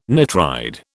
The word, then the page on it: nitride: British English